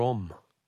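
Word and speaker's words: Homme: Don't pronounce the H in om. Oh, so it's Dior Om.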